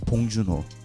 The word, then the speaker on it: Bong Joon-ho: I'm going to say it more Korean. Bong Joon-ho. That's how you say it.